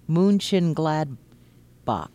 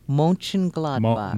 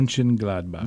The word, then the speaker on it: Mönchengladbach: Uh, o uh, umlaut is O, so it's Mon- Gladbach. Mönchengladbach. Mo- Mönchengladbach. – Mönchengladbach. I think that's pretty close. – It allows retailers from the German city of Mönchengladbach yeah.